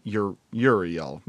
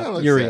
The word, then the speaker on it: Uriel: Yeah, it's Uriel. Yeah, Uriel.